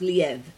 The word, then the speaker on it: Liev: Liv Shriver. – Yeah. – Or Liev. – Actually, he said his name is pronounced Liev.